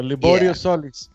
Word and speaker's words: Liborio Solis: There you go, Liborio Solis.